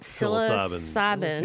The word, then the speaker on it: psilocybin: Psilocybin.